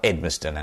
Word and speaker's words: Edmiston: And I'm John Edmiston. And if you're typing it into Amazon, my last name is spelled E-D-M-I-S-T-O-N.